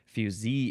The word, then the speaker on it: fusee: This is spelled F-U-S-E-E, pronounced fusee.